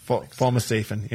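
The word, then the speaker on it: fomesafen: fomosafen. Yeah, I don't want to use the what's, how do you say it? Fomosafen? – Yes. That is correct.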